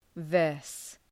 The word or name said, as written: verse